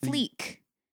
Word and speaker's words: fleek: Yeah, it is pronounced fleek. – Oh, it's pronounced like that? – I said fleck because I've seen it with one E. So I thought it's on fleck.